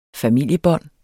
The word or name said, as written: familiebånd